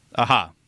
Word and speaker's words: Aja: I think Alexandre Ahi, is that how you pronounce his name, or is it Ahi? – Alexandre Aha. – Aha. Okay. So I thought it was A-G-E. Is it A-J-A? – It's AJA.